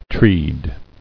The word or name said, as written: Treed